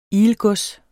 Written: ilgods

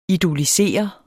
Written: idolisere

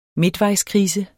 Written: midtvejskrise

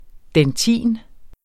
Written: DENTIN

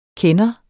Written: kender

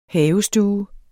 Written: havestue

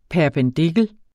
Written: PERPENDIKLEN

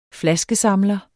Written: flaskesamler

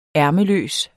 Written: ærmeløs

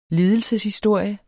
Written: lidelseshistorie

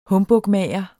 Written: humbugmager

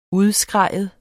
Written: udskrige

